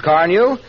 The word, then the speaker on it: Carnu: If I were you, I'd buy a package of Johnson's Car New and clean and polish my car this week. You won't regret it. It's spelled C-A-R-N-U, Johnson's Car New.